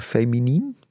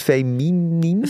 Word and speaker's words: feminien: Hele masculine mannen en wat meer, ik kan het nooit uitspreken, feminien? – Ja. – Is het feminien? – Feminien. – Nien. – Feminien.